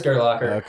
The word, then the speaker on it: Gerlacher: Yeah, nobody claps for me at work, so this is is it Gerlacher? Are we pronouncing it right? – Yeah, it is Gerlacher.